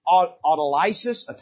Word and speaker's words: autolysis: They're called this, autolysis, I think is actually how it's pronounced, bloat, active decay, and skeletonization.